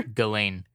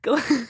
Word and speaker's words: Ghislaine: Ghislaine. Ghislaine. – Can you write that out phonetically? – Just think of it as like G-E-L-A-N-E. – That's Ghislaine, not going to help. – But it's literally G-H-I-S.